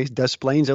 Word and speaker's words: Des Plaines: I remember we, I grew up near Des Plaines, and they'd say, "No, you pronounce the s's." And I go, "Okay, Des Plaines, Illinois."